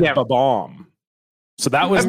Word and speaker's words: Bob-omb: How do you say that particular character? – I would say Bob-omb. – It is a Bob-omb.